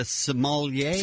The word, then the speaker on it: sommeliers: What are the experts, the sommeliers? – Sommeliers. – Is that how you say it? – Mm-hmm. Yeah.